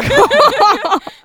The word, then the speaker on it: Caen: elle reçoit le prix de liberté à Caen. – D'accord, ah oui, c'est quand même... – Voilà. T'as dit où ? À Caen ? Caen ? C-A-E-N ? – À Caen ? – À Caen !